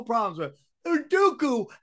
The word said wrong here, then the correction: Udoka: has to be, or what's his name? How do you say Udoka. – It? Udoka. Udoka has to be the dumbest scumbag in the history of dumb scumbags, okay? – And that's me and Sam saying that.